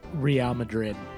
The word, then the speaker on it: Real Madrid: Real Madrid.